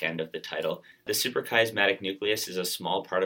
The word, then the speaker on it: Suprachiasmatic: You just published a paper recently in PNAS, and the title of this paper is Functional Network Inference of the Suprachiasmatic Nucleus. Did I pronounce that right? – That is correct.